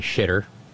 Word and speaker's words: Xitter: Marge offered up a tribute to September the 11th over on Shitter, Xitter, the X pronounced SH, posting a picture of the firefighters hoisting the American flag amid the rubble of the Twin Towers.